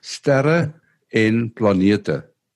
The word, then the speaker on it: Sterre en planete: So, this program, forgive me if I don't pronounce it correctly, Stere an Planeta. – Planeta? Stare an Planeta, yeah say it one more time. Sterre en planeta. Ah, sterre and Planeta. That's it.